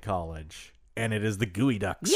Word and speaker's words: geoducks: Is it D-U-C-K-S? – D-U-C-K-S, but it's spelled G-E-O-D-U-C-K-S. – I think I knew that. – It's spelled Geoducks. – Geoducks? – But it's pronounced Gooey Ducks? – Gooey Ducks, yeah.